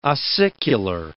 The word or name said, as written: ossicular